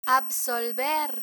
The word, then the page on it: absolver: Spanish